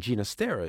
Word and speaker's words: Ginastera: And yes, that's how he himself pronounced his name. His father was Catalan, and Ginastera is the Catalan pronunciation.